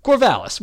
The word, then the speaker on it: Corvallis: Corvallis, we got it.